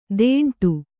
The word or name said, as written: ತೆಂಟು